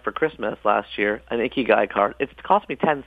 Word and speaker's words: ikigai: One of the words they do have, though, and a word that you like a lot is ikigai. Tell us about that. – Sure. Ikigai, which is spelled I-K-I-G-A-I, is a Japanese term that means the reason you get up in the morning.